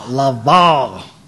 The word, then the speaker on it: LaVar: You got to say it like Shannon Sharp. LaVar. – LaVar.